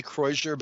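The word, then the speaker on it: croissure: There's a thing called a croisure, and it's a French word, and I'm sure that it is something more like croisure, but I'm not a French speaker, and so I say croisure because it acknowledges the fact that I'm not speaking French, but I'm also helping people to spell it, because it's C-R-O-I-S-S-U-R-E, and it means crossing.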